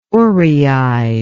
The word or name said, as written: oreide